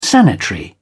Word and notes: sanitary: British English

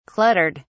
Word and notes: cluttered: British English

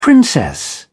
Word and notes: princess: British English

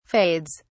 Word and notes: fades: British English

